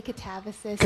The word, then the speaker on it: Katabasis: I think you can say Katabasis, but I really don't mind how you pronounce it.